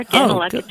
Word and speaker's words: Daneluk: Ganilecki, just like it's spelled.